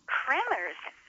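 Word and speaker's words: Kremer's: It is actually spelled K-R-E-M-E-R. – Huh. – Kramer's Drugstore. – Huh. – But I think in the show they also pronounce it Kramer.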